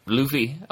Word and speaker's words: Luffy: Luffy.